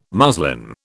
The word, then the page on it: muslin: British English